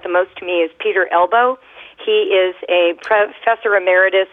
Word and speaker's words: Elbow: And it is spelled E-L-B-O-W. It is Peter Elbow. – Just like your elbow.